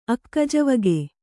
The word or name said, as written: ಅಕ್ಕಜವಗೆ